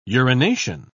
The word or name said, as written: urination